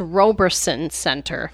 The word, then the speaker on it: Roberson: Some people call it the Robertson Center in Binghamton, but it's pronounced Roberson Center.